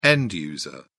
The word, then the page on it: end user: British English